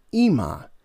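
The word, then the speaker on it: Ema (אמא): So it would be back then it would be pronounced Aim, but now in the modern Hebrew we pronounce it Ema, E-E-M-A, Ema.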